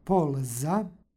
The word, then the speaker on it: polza: U korenu reči stoji tvrdo l, tako da izgovaramo polza.